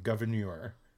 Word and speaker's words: Gouverneur: It's like a French spelling. It's G-O-U-V-E-R-N-E-U-R, Gouverneur. – Oh, he's Gouverneur. Not just a governor? – No, that's his name, Gouverneur.